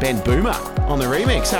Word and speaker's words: Bohmer: I found out by our mate Duncan, who works for Injuna Beats, that this next remixer, his name is actually not Ben Bohmer. It's pronounced Ben Boomer. – Really? – Yes. – What? – There's no H. You don't pronounce it, so it's Ben Boomer, like, you know, schooner.